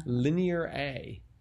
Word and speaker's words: Linear A: Linear A. – Oh. I kept trying to pronounce it as lineara, but oh. I see it's linear A.